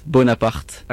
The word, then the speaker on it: Bonaparte: Okay, so it's Bonaparte. – Okay. – With a strong R.